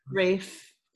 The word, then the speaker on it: Ralph Fiennes: I thought the Tooth Fairy in Manhunter was so much more sinister than, was it Ralph Fiennes or Rafe Fiennes? – Rafe. – Yeah, if you're of a certain wealth level, you just pronounce it Rafe rather than Ralph. – But it's got an L in it, so it's Ralph.